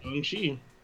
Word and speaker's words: Shang-Chi: Well, no, according to Kevin Feige, it's pronounced Shang-Chi.